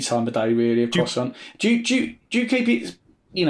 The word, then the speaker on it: croissant: But at number five, the croissant. – Ooh. – Am I saying it right? – Croissant.